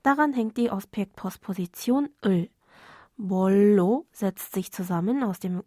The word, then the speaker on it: 뭘로: Eigentlich lautet diese Zusammensetzung grammatisch korrekt moro, wird aber in der Umgangssprache oft, wie in unserer Szene, als Bollo ausgesprochen.